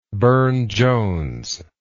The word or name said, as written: Burne-Jones